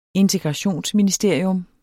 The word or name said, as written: integrationsministerium